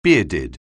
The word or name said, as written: bearded